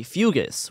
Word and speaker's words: fugue: In some cases, terrifying visions were followed by fugues, which is an old term for, like, fugues. It says fugues. – Yeah, fugue. Fugues. It's pronounced fugue.